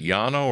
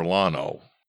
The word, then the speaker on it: Llano: Let's talk about the, how do you pronounce it, Yano or Lano? – Well, it depends which state you're in, but you're on the right track. – Okay. – So it's a double L to start, L-L-A-N-O.